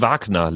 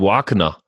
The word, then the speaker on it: Wagner: Wird's, ich weiß nicht, spricht man ihn jetzt, weil er in England gewirkt hat, einfach David Wagner aus, oder? Er ist ja auch... Ja, David Wagner letztendlich, David Wagner, die Wagner-Pizza.